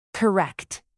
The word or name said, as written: correct